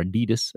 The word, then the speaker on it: Adidas: His Adidas deal, or Adidas, as Americans say, was worth about $1.4 billion.